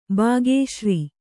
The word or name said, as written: ಬಾಗೇಶ್ರೀ